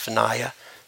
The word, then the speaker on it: Vinaya: and that became the Vinaya. Sometimes you'll hear it pronounced Vinaya, monastic code.